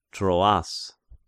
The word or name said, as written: Troas